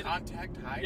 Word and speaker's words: contact high: Did I tell you about the time, dude, I freaking contact-hide... I got the kid... – Contact high? – Yeah, that wasn't the right way to pronounce it.